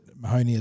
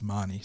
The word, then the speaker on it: Mahoney: He came across from the Canterbury Bulldogs to us as a 16 or 17-year-old, and I initially also pronounced it Mahoney as well until I was because that's just how it reads phonetically, but then I was corrected very quickly, very early on, that it's Marnie.